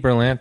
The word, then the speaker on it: Berlant: Thank you for saying it right, because people will say, I feel like my name is very, it's like phonetic, Berlant. It's pretty clear. People will say Berlant.